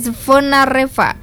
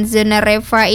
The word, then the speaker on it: Zvonareva: Gimana nih bacanya? Z sama V. Zvonareva.